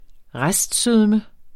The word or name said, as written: restsødme